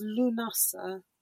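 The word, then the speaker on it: Lughnasa: How do you say the next one? Lammas? I've put it down as Lammas, but I don't know if that's how you pronounce it. – So it's Lunasa. – Lunasa? – Yeah, and it's the 1st of August, so it marks the first harvest.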